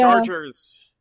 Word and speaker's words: Darger: Or one of the many other ways it's potentially pronounced. – Yes. Darger, yeah. Darger. – Darger. – Darger. Darger. – There seemed to be... it seemed to be up for debate. – Darger seems to... That works for me.